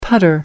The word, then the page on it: putter: American English